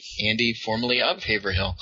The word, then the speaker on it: Haverhill: And those who don't live around Massachusetts, it's Haverhill. It's pronounced Haverhill.